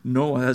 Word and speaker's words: NOAA: And NOAA, I don't know if that was a coincidence. Well, I don't think it was, but Noah is how it's pronounced.